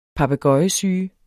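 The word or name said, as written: PAPEGØJESYGEN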